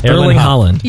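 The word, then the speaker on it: Erling Haaland: No, you're making it harder than it is. Erling Holland.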